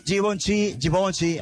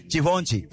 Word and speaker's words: Givenchy: Givenchy, así se pronuncia Givenchy. – ¿Cómo? – Givenchy.